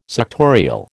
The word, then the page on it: suctorial: American English